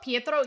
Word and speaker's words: Pietro: Pietro, he is Pietro.